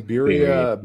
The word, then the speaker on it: birria: The birria. – Yeah. Birria. Like popular stuff right now. Yeah. That's have you seen the pizza? No. I did have some birria ramen.